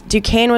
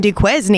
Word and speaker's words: Duquesne: It was so funny because I was playing on Broadway just the other day, and it was Duquesne was playing BYU, March Madness.